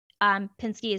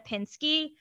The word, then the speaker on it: Pinsky: So that's spelled I-L-A-N-A. Pinsky is P-I-N-S-K-Y. My YouTube channel is just simply my name, Alana Pinsky. I'm on TikTok as Alana Pinsky, but there are two Ys. So it's Alana, I-L-A-N-A-P-I-N-S-K-Y-Y.